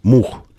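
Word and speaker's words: MU-X: А в целом это читается как мух.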